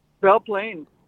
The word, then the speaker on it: Belle Plaine: You say it like, why do you say it like Mel? – Mel? Belle Plaine. – Mel Plaine. Like, how do you say B-A-G? – B-E-L-L-E. – Oh, Bell. Okay.